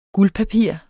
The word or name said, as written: guldpapir